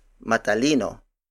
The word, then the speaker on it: matalino: To use it as an actual adjective, we add the MA syllable at the beginning, so it becomes matalino matalino, matalino. Matalino is spelled as M A T A L I N O. It means intelligent or smart, someone who possesses intelligence. Again, the Tagalog word for intelligent or smart is matalino.